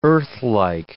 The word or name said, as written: earthlike